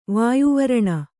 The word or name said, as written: ವಾಯುವರಣ